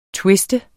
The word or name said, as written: twiste